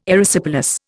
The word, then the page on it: Erysipelas: Indian